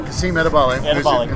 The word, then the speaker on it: Edebali: And that's because, like, when he gets beat, so, like, if Bond beats him around the edge, Edebali, am I saying it right? – Yeah, Kasim Edebali.